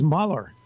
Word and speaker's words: Mueller: People who get the pronunciation of his name wrong. You just go on Google, and it'll tell you it's Mueller. Now, I'm familiar with two with the same spelling. Don Mueller, M-U-E-L-L-E-R.